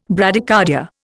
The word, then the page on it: Bradycardia: Indian